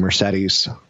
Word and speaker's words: Mercedes: Mercedes is supposed to be Mercedes.